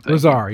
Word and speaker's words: Lazar: Lazar, yeah, there you go. – Yeah, so like I said Laser. I was mispronouncing it.